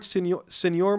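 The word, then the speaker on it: Señor: Senor Moriarty? – Yeah. – Okay. Senor Moriarty. Because it has a little tilde. – The tilde over the end. That's a nye.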